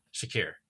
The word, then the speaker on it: Shakir: How do you say his name? Is it Shakir? Shakri? Sha- Shakir or Shakir? – Yeah, Shakir.